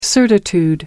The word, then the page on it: certitude: American English